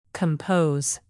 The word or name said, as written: compose